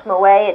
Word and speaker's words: Mauet: There is a book, Fundamentals of Trial Techniques, which is by an author, I pronounce Mauet, it's M-A-U-E-T.